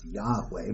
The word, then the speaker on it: Yahweh: the consonants or in English would be YHWH uh, with uh, negotiable vowel points, and we think it was probably pronounced Yahweh, but we don't know because people stopped pronouncing it because it was too sacred.